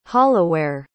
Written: holloware

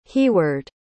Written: heahweard

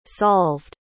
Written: solved

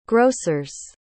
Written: grossers

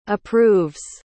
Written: approves

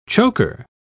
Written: choker

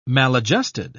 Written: maladjusted

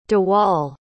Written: dewal

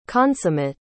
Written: consummate

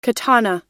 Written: katana

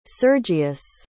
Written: sergius

